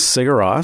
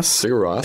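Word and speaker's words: Sigur Rós: So this is Cigaros. – Cigaros.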